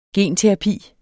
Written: genterapi

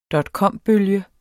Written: dotcombølge

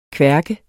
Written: kværk

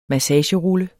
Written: massagerulle

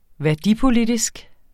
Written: værdipolitisk

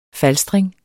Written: falstring

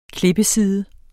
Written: klippeside

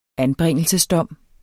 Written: anbringelsesdom

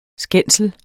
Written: skændsel

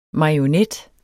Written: marionet

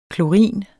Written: klorin

